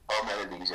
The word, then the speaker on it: Parmenides: So Zeno, he was a pupil of Parmenides, how do you say that name? – Oh, Parmenides, is it? – Parmenides, yeah.